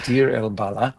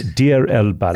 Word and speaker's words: Deir el-Balah: So I think the way that it's pronounced in Arabic, and of course my Arabic is almost as good as yours, but I think it is Deir el-Balah.